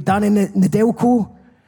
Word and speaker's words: Danny Nedelko: Danny Nedelko.